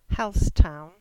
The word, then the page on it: Halsetown: English